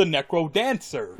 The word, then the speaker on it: Necrodancer: No, it's called Crypt of the Necrodancer. – Yes. – Oh, dancer. I thought you were saying Nancer.